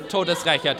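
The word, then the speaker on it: Todesrächer: Todesrecher, der Todesrecher von Soho, yeah. That's literally means like death, the death avenger of Soho. – Okay, not toad scraper or something like that.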